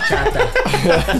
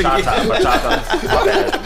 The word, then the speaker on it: bachata: And it's pronounced like, bachata. – So I used to hate doing American. – I'm sorry. They looked at you crazy. – Bachata.